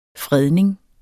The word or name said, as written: fredning